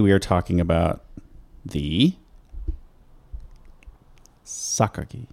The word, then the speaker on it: Sakaki: And I learned how to say this name. I looked it up on the internet, as I am wont to do. And it is Sakaki. – Yes. – And I love that. I had to remember that Japanese pronunciations are vastly different than pronunciations of Latin-based languages.